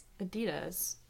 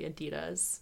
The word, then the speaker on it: Adidas: And what do you say? – Adidas. – And that is how soccer players say it correctly. So you're saying it correctly, Adidas.